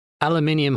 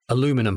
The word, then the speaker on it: aluminium: Aluminium hydroxide, aluminium phosphate, and aluminium salts. And by the way, that's the English pronunciation. In America, you would say aluminum hydroxide, aluminum phosphate, and aluminum salts.